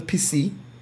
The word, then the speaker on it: Pussy: Pussy, French pronunciation, PC, is a small village in the commune of La Lachère and Sauveur department of France.